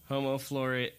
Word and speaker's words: Homo floresiensis: Homo floresiensis.